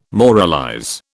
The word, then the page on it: moralize: British English